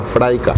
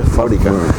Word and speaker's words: fábrica: en vez de decir fábrica, decía fraica. Y abuela no se dice fraica, se dice fábrica.